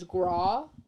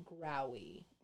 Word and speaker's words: Grawe: I thought it was Graw, but people in, like, videos that I've watched, they pronounce it Growie. – Mm-hmm. – So spelled G-R-A-W-E.